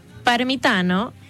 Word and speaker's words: Parmitano: Y bueno, te cuento que estos cuatro astronautas son Randy Bresnik, que es comandante de la misión, Luca Parmitano, piloto, Frank Rubio, que es especialista de misión, y André Douglas, que es especialista de emisión. – Esta Parmitano, y que lo pronunciaste muy italiano.